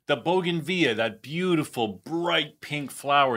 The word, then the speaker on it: bougainvillea: Am I saying it right, guys? Bougainvillea? – I think so. That's how I've always said it.